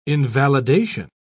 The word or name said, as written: invalidation